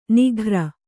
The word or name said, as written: ನಿಘ್ರ